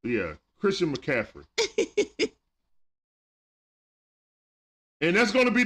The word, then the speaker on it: McCaffrey: It's McCaffrey, not... Cafferty.